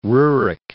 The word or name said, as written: Roerich